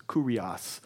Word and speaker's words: kurios: And this is kurios. K-U-R-I-O-S would be the way we would spell it in English. But it's not like curious, like weird, but kurios, which literally means, guess what?